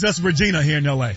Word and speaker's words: Regina: It's Regina. That's the way it's pronounced.